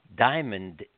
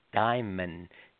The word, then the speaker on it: Daimon: And he said, Bernie, that's not Diamond is not the name of the cat. It's Diamond. D-A-I-M-O-N.